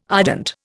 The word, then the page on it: ardent: Indian